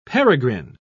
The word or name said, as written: peregrine